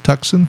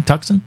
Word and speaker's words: Tucson: I think it's pronounced Tucson. – Tucson. Tucson. Like the Hyundai.